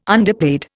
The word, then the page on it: underpaid: Indian